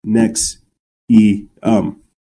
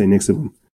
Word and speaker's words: NXIVM: Keith Raniere founded a quote personal and professional development company called NXIVM, pronounced Nex E um. I say Nixivum in 1998 in Albany, upstate New York.